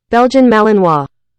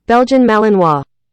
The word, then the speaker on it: Belgian Malinois: Belgian Malinois. – Maybe I didn't get it. Can I get it one more time? – Belgian Malinois.